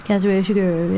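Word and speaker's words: Kazuo Ishiguro: Kazuo Shiguro.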